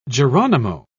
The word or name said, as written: geronimo